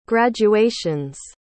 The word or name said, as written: graduations